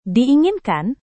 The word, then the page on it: diinginkan: indonesian